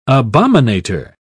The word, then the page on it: abominator: British English